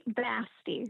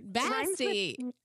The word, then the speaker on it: Basti: Kate Basti. – Basti. – Rhymes with nasty. – It does rhyme with nasty.